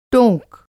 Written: टोंक

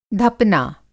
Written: धपना

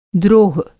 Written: द्रोह

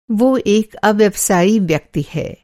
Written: अव्यवसायी

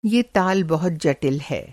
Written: ताल

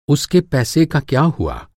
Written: होना

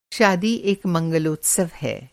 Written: मंगलोत्सव

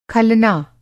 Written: खलना

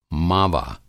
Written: मावा

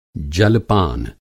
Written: जलपान

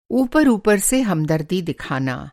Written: ऊपर-ऊपर

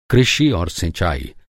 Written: सिंचाई

